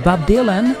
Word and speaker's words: Bob Dylan: Sí lo dije bien, es Bob Dylan.